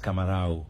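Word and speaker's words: camarão: Et, quand les avons vu, il m'a vérifié encore avec un ami, Jeremias, qui cause portugais, et il confirme avec moi qu'il prononce camarao. Et, ça, c'est l'origine, qui se dit camaron, il sortit de camarao.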